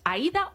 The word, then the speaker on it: Aída: Bueno, pues es que Aída ha cazado, nunca sé si es Aída o Aida. – Aída. – Sí, es que hay gente que lo pronuncia a Aída. – De hecho, creo que ella misma lo pronuncia como Aída.